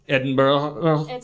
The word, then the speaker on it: Edinburgh: Edinburgh, which is spelled Edinburgh, but then it's a trap.